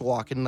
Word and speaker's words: Walkom: It's a guy named Nelson Walken, but Walken is actually, it's pronounced Walken, like Christopher Walken, but his name is W-A-L-K-O-M.